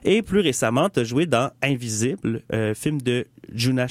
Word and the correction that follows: Yuna: Schiff, si je le prononce comme il faut. – Presque, vas-y donc. – Yuna,